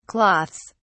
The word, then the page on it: cloths: British English